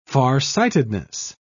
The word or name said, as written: farsightedness